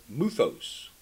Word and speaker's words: muthos: Now, I looked it up in Strong's, and the word fables is translated from the word muthos, M-U-T-H-O-S.